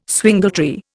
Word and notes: swingletree: Indian

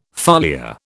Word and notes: foliar: British English

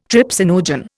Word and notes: trypsinogen: Indian

